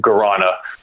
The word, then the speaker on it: guarana: One of the things I thought was really interesting was that your beverage brand is made with guarana. Guarana? Is that the right way to say yeah. – it? – Yeah.